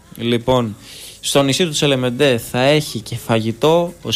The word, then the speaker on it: Τσελεμέντε: Τσελεμέντε, συγγνώμη. – Τσελεμέντε, ναι. Γαλλικά θα το τονίζει.